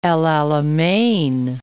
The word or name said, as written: El Alamein